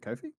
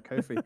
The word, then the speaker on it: Ko-fi: Yeah, for example, they can make money on Ko-fi. Is it Ko-fi? – Yeah. – Did we figure out the pronunciation earlier? – Ko-fi, no fees. – Yeah, Ko-fi.